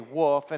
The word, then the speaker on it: Wolf: And some people think I don't say this correctly because I say Wolf, and they're like, there's an L in it. And I'm like, Wolf, it's made in Texas. So you got to say it like a Southerner. So you got to trust me. It's Wolf brand. That's how you say it, like the sound a dog makes, the sound of this chili.